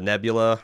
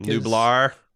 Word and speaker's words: Nublar: Yeah, which that was the setting, you know, is of Isla Nebula, or I forget. – Nublar, I think. – Nublar.